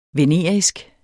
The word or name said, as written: venerisk